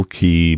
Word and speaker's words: Quay: You can order it on Amazon or at Castle Key Books. Key is spelled Q-U-A-Y.